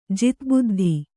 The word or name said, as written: ಜಿತಬುದ್ಧಿ